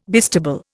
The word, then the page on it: bistable: Indian